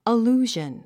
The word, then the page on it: allusion: əlúːʒən; アルゥジャン